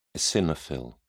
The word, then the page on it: eosinophil: British English